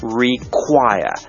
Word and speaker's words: require: Do you require ค่อนข้างจะเป็นภาษาทางการนะครับแต่สุภาพดีสุภาพมากกว่า Do you want ซึ่งมีความหมายเดียวกันนะครับ Do you require สุดท้ายนี้นะครับการออกเสียงคำนี้ไม่ยากนะครับเพียงแต่ว่าเน้น require เน้นพยางค์ที่สองนะ